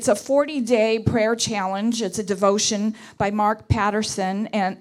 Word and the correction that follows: Batterson: or Batterson is how you say his name, called Draw the Circle.